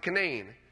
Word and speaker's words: Canaan: You know, in West Virginia, there's a place called Canaan Valley, spelled the same way as Canaan, but they pronounce it Canaan.